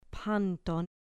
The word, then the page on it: panton: grec